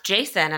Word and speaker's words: Jacin: I do remember hearing her say that it's pronounced Jason.